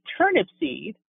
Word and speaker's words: Turnipseed: It's a long name and kind of a funny name, but it is really pronounced exactly as it's spelled. And when people hear that name, they're often surprised, and sometimes they stumble over it, and I say, well, it's like a seed of a turnip. Turnip seed